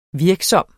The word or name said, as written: virksom